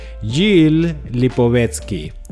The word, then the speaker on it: Gilles Lipovetsky: Es un filósofo francés de nombre Gilles Lipovetsky. ¿Sí lo dije bien, dime. – Sí, así es, está muy bien.